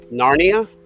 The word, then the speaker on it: Narnia: Greta Gerwig has reportedly already secured a deal to write and direct a minimum of two films based on the Chronicles of Nara. Did I pronounce that right? – Narnia.